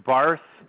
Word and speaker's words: Barth: My name is Barth, as in boy, B-A-R-T-H. Last name, Connard, C-O-N-A-R-D. – You said C-A-N-O-R-D? – C-O-N-A-R-D.